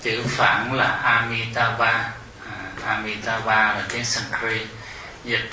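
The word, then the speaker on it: Amitābha: A Di Đà Phật âm Hán Việt á, nhưng mà chữ Hán, chữ phổ thông tiếng phổ thông Hoa là nó không có chữ D, cho nên đọc là chữ M, đọc là Mi Mi Amitabha. Amitabha theo cái chữ phạn,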